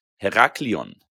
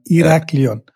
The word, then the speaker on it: Heraklion: Hier spricht man nicht aus. Also wenn du Punkte machen willst, sondern Heraklion. – Heraklion. Aber man sagt dann auch Heraklio, weil das N spricht man schon gar nicht mehr in Griechenland. Zumindest beim Heraklion nicht.